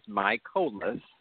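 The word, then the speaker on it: Mikolas: And I believe that the bringing in of Miles Michaelis, and by the way, that is how – you know, we were told to pronounce his name. It is Michaelis.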